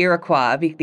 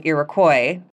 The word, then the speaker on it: Iroquois: So as someone raised in Canada, I default to Iroquois, the original French pronunciation. But living in Georgia, here in the southeastern United States, what I hear most commonly here is the anglicized version, Iroquois.